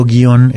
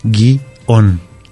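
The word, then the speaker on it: guion: Sin embargo, guión es un monosílabo, tiene una sola sílaba. Su separación correcta en sílabas no es guión, uh-huh. – es guión, es una sola sílaba.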